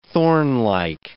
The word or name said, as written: thornlike